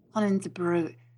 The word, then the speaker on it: Koningsbruggen: It is Rob Van Koningsbruggen. – Koningsbruggen. You made it sound Scottish. – Koningsbruggen. Oh, Van Koningsbruggen. There, is that better? – There you go.